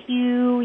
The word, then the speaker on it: HU: You can do it out loud. Hew. You can whisper the hew. It sounds like the wind. You can also spell it, H-U.